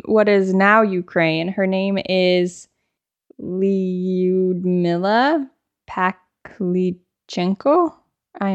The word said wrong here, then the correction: Pavlichenko: Lyudmila Pavlichenko.